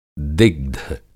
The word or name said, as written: दिग्ध